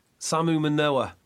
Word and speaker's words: Samu Manoa: Not Manu... Samu Manoa. There we go. Not Manu Samoa, as I like to call him.